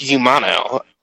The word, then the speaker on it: Humano: I think it's pronounced Humano, like Hermano.